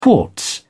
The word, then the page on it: quartz: British English